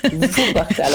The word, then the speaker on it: Wuppertal: Et Huppertal encore pire. – Wuppertal avec W, avec V.